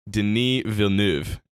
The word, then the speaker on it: Denis Villeneuve: I believe he pronounces it Denis Villeneuve. Is I think how it goes.